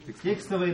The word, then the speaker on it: текстовая: Текстовая или текстовая? Текстовая. Ударение на «е», да?